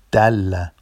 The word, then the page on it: dalla: Vafsi